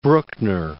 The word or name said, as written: Bruckner